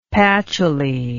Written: pachouli

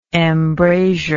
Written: embrasured

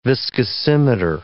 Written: viscosimeter

